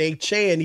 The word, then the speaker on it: Achane: Devon Achane, that's how you say it, Achane.